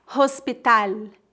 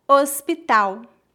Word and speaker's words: hospital: Lembre-se que o H é completamente mudo no português e que o L tem som de U em final de palavras. Portanto, não dizemos hospital, e sim hospital.